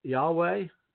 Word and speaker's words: Yahweh: which would actually pronounce Yehovah or Yahweh, it's the Hebrew characters Yoh, He, Va, and He.